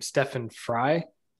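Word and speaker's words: Stefan Frei: Stefan Fry.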